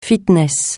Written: fitness

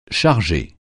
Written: charger

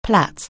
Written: plait

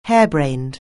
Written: harebrained

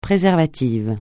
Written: préservatif

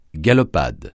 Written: galopade

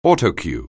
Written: Autocue®

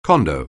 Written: condo

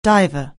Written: diver

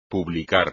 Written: publicar

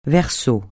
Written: verso

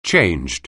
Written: changed

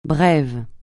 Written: brève

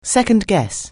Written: second-guess

